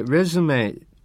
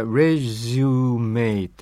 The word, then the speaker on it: レジュメ: さてですね、この、レズメというような発音やレジュメという発音、いろいろあります。